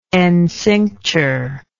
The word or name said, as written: encincture